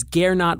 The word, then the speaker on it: Gernot: This is Gernot Wagner, a tricky name to pronounce for Americans. Um, Gernot Wagner, uh, juggernaut without the jug.